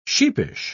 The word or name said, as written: sheepish